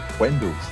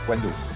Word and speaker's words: Wendels: No, it's not, neither. It's Wendels. The alliteration is WW. Wendels.